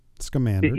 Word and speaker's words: Scamander: Scamander.